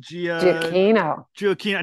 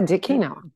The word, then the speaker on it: Giacchino: Well, the director, I'm going to get the name right, Michael Giacchino.